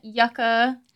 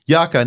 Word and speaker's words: yucca: So it's a Mexican yucca tree. – Yucca, not yucca.